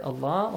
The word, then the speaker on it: الله: The letter lam in the word Allah also must be pronounced with a light sound. – Yeah. – Because there is, Hussein, a kasra before it.